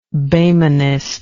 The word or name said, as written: Boehmenist